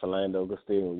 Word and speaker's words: Philando Castile: Yeah, Philando Castile, yeah.